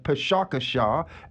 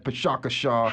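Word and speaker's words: Pecha Kucha: So if someone needs something to do tonight, I'm going to be there Pshakashaw. – How does one spell Pshakashaw? – That's a very good question. It is spelled P-E-C-H-A. – K-U-C-H-A, Pshakashaw, a lot of different pronunciations.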